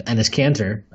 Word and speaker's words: Enes Kanter: I know that they have Enes Kanter, um, which uh, funny enough, as Matthew Miranda posted out or pointed out on an art in an article this week on Posting and Toasting, rhymes with penis cancer.